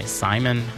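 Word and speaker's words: Szymon: Up next, we're going to have a song by Simon. I assume you say it. That's how you say it. It's S-Z-Y-M-O-N, an Australian...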